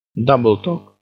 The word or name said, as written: double-talk